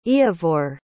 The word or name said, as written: iavor